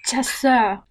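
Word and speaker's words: Chasseur: Chesur. Eso. es que es francés, es un término en francés, entonces se pronuncia así. El AU se pronuncia eh, como si fueras a vomitar.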